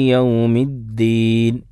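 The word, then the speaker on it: യൗമിദ്ദീൻ (الدين): എന്നാൽ അമിതമായിട്ട് ധീൻ എന്നുള്ള രൂപത്തിലുള്ള ഉച്ചാരണം പാടില്ല. ഇപ്പോൾ ഉച്ചാരണ തെറ്റ് പറയുമ്പോൾ ചില ആളുകളൊക്കെ ദാല് ഉച്ചരിക്കുമ്പോൾ മാലിക്കി യൗമിദ്ദീൻ യൗമിദ്ദീൻ ശ്രദ്ധ കൊടുക്കുമ്പോൾ അങ്ങനെയാണ് അതിന് ഉച്ചരിക്കേണ്ടത്. അതിന് പകരം മാലിക്കി യൗമിദ്ദീൻ, ദാലിന് ഒരു ഹാഇൻ്റെ ശബ്ദം കൂടി കൂട്ടി ഒരു കാറ്റ് വരുന്ന രൂപത്തിലുള്ള ഉച്ചാരണം എന്താണ്, അത് ശരിയല്ല.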